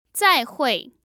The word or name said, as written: zaihui